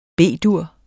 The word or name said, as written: B-dur